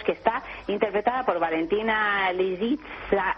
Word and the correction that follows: Lisitsa: lo he dicho bien. – Lo has dicho fatal. – A ver cómo es. – Pues hombre, Lisitsa. – Lisitsa, con S, no, has dicho otra cosa completamente distinta.